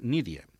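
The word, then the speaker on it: Nydia: Continuem amb una altra de les peces que m'ha seleccionat, que d'en Juli Garreta, que és Nídia, si pronuncio bé, amb Y, oi? – Sí.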